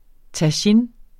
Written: tagine

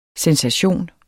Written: sensation

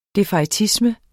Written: defaitisme

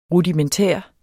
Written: rudimentær

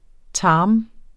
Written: tarm

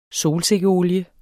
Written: SOLSIKKEOLIE

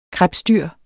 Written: krebsdyr

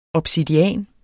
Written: obsidian

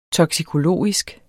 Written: toksikologisk

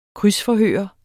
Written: krydsforhør

